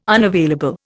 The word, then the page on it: unavailable: Indian